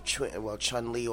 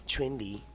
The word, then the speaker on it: Chun-Li: You know, when I think about the character Chun-Li, or in this case, in accordance to Mandarin pronunciation, is Chun-Li.